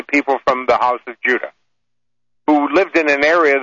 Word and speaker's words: Judah: The 2 southern tribes were Benjamin and Judah, or Judah, as we pronounce now that we have a harder J sound in our language.